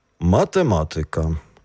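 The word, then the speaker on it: matematyka: Наголос пропарокситонічний, тобто на третій склад від кінця слова, є обов'язковим теж у словах, які закінчуються на «ка». – Математика, музика, логіка, пропарокситоніка.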